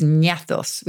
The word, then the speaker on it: gnathos: So ganache actually comes from the Greek for jaw, which is gnathos with a silent G, G-N-A-T-H-O-S.